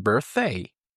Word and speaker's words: birthday: ما این دوتا رو به هم میچسبونیم، به جای when is میگیم when's your birthday؟ تلفظ birthday رو هم دقت کنید، نگید birthday یا birthday. زبونتو بذار بین نوک زبونتو بذار بین دوتا دندونات،